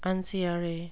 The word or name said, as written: unciary